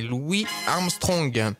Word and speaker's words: Louis Armstrong: Alors, je disais Louis Armstrong, mais je me suis trompé, on prononce ça la française. C'est Louis Armstrong.